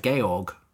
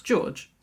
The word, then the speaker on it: Georg: We learn his name here at this point, where it's like Georg. – Mm-hmm. – It's George. It's George.